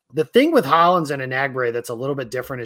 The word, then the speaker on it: Enagbare: But the practice I was at, Hollins was ahead of Enagbare, and I think it's Enagbare, I've been told, is the way that that's pronounced. So we've all been pronouncing his name wrong for over a year.